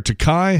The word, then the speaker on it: Takei: George Takei, or Takei, however you like to pronounce it, 86.